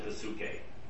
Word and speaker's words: psyche: The second word for life is psyche, which we would use as the prefix psych, P-S-Y-C-H. In Greek, they would pronounce it psyche. There are no silent letters in Greek.